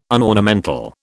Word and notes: unornamental: British English

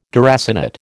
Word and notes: deracinate: American English

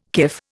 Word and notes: kif: Indian

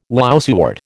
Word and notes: lousewort: American English

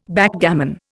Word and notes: Backgammon: Indian

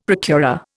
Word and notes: procurer: Indian

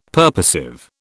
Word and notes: purposive: British English

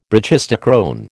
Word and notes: brachistochrone: American English

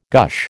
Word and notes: gush: American English

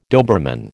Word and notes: Doberman: American English

